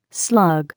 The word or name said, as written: slug